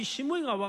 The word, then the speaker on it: 시므이: 그럼에도 불구하고 이 시므이가 와가지고 이렇게 비난했다라는 겁니다. 시므이. 발음 잘하셔야 돼요. 시어머니 아니고. 또 하필 비슷해요. 그죠? 시므이.